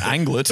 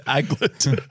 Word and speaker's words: aglet: You're still saying it wrong. It's an anglet. – Yeah, there's no way. – It's aglet. – A-G-L-E-T.